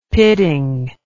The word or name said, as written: pitting